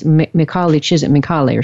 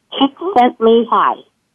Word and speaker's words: Csikszentmihalyi: If you get his book called Flow, Now look on the very back. Look on the very back cover, and it says on the back cover how to pronounce his name. And okay. – Csikszentmihalyi. – Csikszentmihalyi. Wow, that is totally different from what I've been saying.